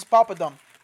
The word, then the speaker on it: papadum: This is papadum.